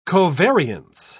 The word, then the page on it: covariance: British English